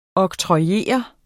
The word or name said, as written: oktrojere